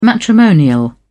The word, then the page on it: matrimonial: British English